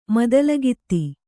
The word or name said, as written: ಮೊದಲಗಿತ್ತಿ